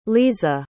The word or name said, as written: leza